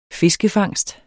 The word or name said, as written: fiskefangst